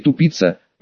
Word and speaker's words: Тупица: И, думаю, в то время я бы лопнул от хохота, узнав о наличии в области деревни Тупица, вообще-то с ударением на первый слог.